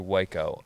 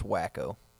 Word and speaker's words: Waco: Like we leave College Station and we're going through Waco up. Yeah. And it's pronounced Waco.